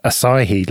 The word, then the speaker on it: Asahi: Asahi Linux, I think that's how you say it. – Like the beer.